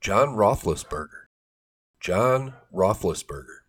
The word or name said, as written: John Roethlisberger